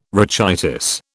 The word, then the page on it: rachitis: British English